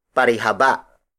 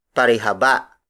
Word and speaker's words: parihaba: The last shape on this batch is parihaba. Parihaba. Parihaba. Parihaba is spelled as P-A-R-I-H-A-B-A.